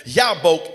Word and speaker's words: Jabbok: And when I looked it up, the correct pronunciation of the word J-A-B-B-O-K, Jabok, as we would call it, Yabok in the original language, it means the emptying.